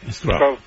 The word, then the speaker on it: crup: CRAP. – Pero sí. Digo, no se dejen guiar. Ah, no, vacuna, no. – Digo, CRAP, CRAP, C-R-U-P, CRAP. – CRAP, C-R-U-P, CRAP. Bueno, CRAP. Pronunciaría en español, CRAP.